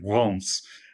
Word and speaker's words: Reims: Reims, or to give its proper French pronunciation, Reims.